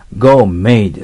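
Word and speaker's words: gourmet: ゴーメットって書いてますけど、これが私たちの日本語になってるゴーメイですよ。 T を発音しないんですね。ゴーメイ。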